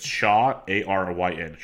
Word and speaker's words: Shawaryn: It's Shaw, A R Y N.